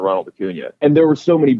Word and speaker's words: Acuña: Acuña, it's got the little yeah at the end.